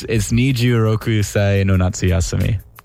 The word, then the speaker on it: Niju Roku Sai no Natsu Yasumi: 26-Siano-Nazi. – Siano-Nazi... One more time. I want to say it right. – So it's Niju Roku Sai no Natsu Yasumi.